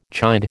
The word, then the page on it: chide: American English